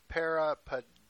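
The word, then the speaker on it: peripadeo (περιπατέω): In Greek, Koine Greek, to walk is peripateo, or as it's pronounced back then, peripadeo.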